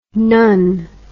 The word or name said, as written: nunn